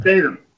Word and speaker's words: Statham: How do you pronounce his last name? – Statham.